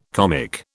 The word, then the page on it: comic: British English